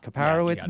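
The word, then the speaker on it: Kaiparowits: They include Escalante Canyons National Park and Preserve, Grand Staircase National Park, Kaparowitz, is that how you pronounce it? – Oh, yeah. – Kaparowitz National Monument and Escalante Canyons.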